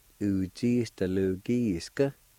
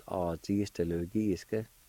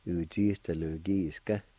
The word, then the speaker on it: ujistalugiska: I said ah and it's supposed to be ooh. So the whole word is oozistalugiska. Should be pronounced like that. And I think last week I said ahzistalugiska.